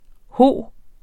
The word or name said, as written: hos